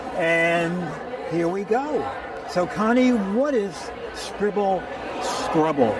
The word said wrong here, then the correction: Scribble Scrubbies: Am I saying it right? – Scribble Scrubbies. – Oh, Scrubbies.